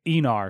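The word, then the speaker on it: Aenar: I was mixing up Andorian and and uh, but it's I looked up the pronunciation. I don't know if they always I don't know if they say Enar in this, but it's supposed to be pronounced Enar.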